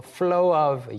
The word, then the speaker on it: flow of: 발음상으로 flow of 이게 약간 floor처럼도 들릴 수 있는데 주의해 주시고 flow of everyone else.